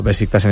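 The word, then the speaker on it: Beşiktaş: Pero no, Besiktas en este caso se lee tal cual se pronuncia. Quizá es verdad que la S se hace un poco H, que me sale a mí más Besiktas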